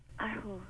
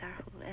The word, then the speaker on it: erhu: How do you pronounce the musical instrument? – Erhu. – Yeah. – Yes, you're very close. You're just right, erhu.